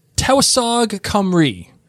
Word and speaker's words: Tywysog Cymru: Yeah, this is the title of this episode 306, is Tywysog, Cymru.